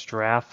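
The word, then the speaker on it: Jirav: Yeah, our predominant forecasting solution is Draf. Sounds like the animal spelled with a J, J-I-R-A-V, is a platform.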